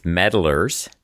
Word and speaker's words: Meddlers: So to say your name again, it's Meddlers. – Yes. – Like to meddle with something. Meddle, right? – That's correct.